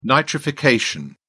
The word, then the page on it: nitrification: British English